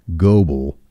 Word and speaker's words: Gobble: Well, it's spelled G-O-B-B-L-E, and his name's Richard Goble. And by the way, I'm not fussing at all. I'm glad that I got corrected. Tabby sent a text and said that it's pronounced Goble, long O. I said, I'm sorry. In North Carolina, where I'm from, this same name is pronounced Gobble.